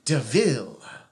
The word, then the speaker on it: DeVille: Dracula Castle is endangered during the 18th century by a pirate named, very aptly, Helen DeVille. Helen is spelled H-E-L-L-Y-N, and DeVille is spelled D-E, capital V-I-L-L.